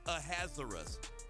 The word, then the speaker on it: Ahasuerus: Or you can say Ahasuerus.